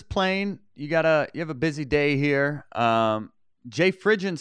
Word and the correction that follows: Fridgen: It's pronounced Fregian.